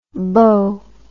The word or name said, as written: boh